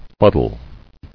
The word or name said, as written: Fuddle